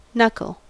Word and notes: knuckle: American English

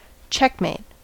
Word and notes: checkmate: American English

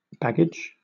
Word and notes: baggage: British English

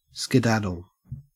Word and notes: skedaddle: Australian English

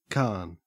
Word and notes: carn: Australian English

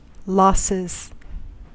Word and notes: losses: American English

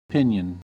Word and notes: pinion: American English

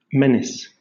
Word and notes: menace: British English

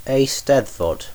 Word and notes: eisteddfod: British English

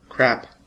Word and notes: crap: American English

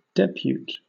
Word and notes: depute: British English

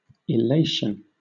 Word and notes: illation: British English

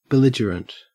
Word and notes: belligerent: Australian English

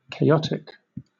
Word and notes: chaotic: British English

